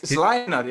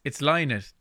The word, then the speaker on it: Lynott: Yeah, actually, you pronounce his name right, but everyone in Ireland pronounces it wrong, including me. I always call him Phil Lynott, but Lynott, it's Lynott, isn't it? – It is, yeah.